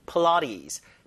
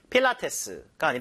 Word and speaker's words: Pilates: 자, 필라테스 발음이 플라티스, 필라테스가 아니라 요 강세가 중간에 있거든요. 사람 이름입니다, 이게요. 플라티스, 푸처럼 주다가 라에 강세 주세요.